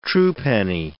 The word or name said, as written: truepenny